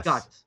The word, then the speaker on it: Guts: Guts. G-U-T-S.